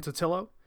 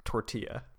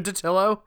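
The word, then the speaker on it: Totilo: So this one comes from Kotaku by Steven Totillo. – I believe it's pronounced tortilla. – It's definitely not. There's no R in his name.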